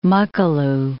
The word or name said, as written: Makalu